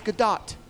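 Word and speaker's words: Gadot: And she said, no, actually, it's Godot. – Oh. – And really pronounced the T.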